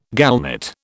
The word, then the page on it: gallnut: British English